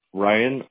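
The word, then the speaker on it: Rhyan: It's going to be my first name, Ryan, R-H-Y-A-N-C-O-L-E. – R-H-Y.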